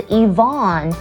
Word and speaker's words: Yvonne: 它的正确念法是 Ivan, 可是很多人把它念成 Ivan, OK, 不对啦，如果是女生的名字 Yvonne, 它的拼法是 Y-V-O-N-N-E。